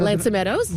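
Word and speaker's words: L'Anse aux Meadows: So we're traveling full time, and we've been here since June 1st, and we've been to Loxon Meadows. I don't know. – Lansa Meadows? – Yeah, I don't know that I pronounced it right.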